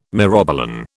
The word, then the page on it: myrobalan: British English